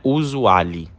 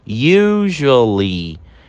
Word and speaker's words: usually: Olha como é diferente a pronúncia dessa palavra. Não é usually, como a gente lê, né? A gente fala, pronuncia corretamente da seguinte forma: usually.